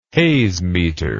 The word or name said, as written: hazemeter